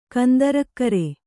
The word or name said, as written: ಕಂದರಕ್ಕರೆ